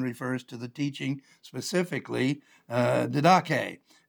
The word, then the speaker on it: didache: The word teaching is the Greek word didache, D-I-D-A. I can't read my own writing here. Didache, D-I-D-A-C-H-E, or didache is the way you would pronounce it with the accent there.